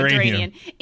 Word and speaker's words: Duranian: Darien? – Duranian. – There you go, Duranian. It's spelt so much differently than it sounds, because it sounds like uranium, but it's not spelt that way at all.